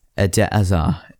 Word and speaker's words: Eden Hazard: So, Eden Hazard is how I'm going to pronounce it.